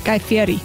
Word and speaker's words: Fieri: Guy Fieri.